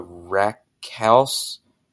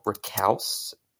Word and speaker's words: raucous: Ridiculous ramblings of a rambunctious rambler, a rhapsody of a raucous reverie. All right, and how do you say this word? Recalce? Raucous. Okay, I'm very raucous.